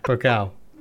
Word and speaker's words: Pokal: DFB Polka? How do you pronounce it? – Polka. – Oh yeah, there's no L before the K. Pokal.